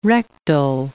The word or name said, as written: rectal